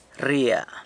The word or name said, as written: rria